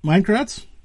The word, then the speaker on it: Maikranz: Anyways, my guest tonight is Eric Maikranz.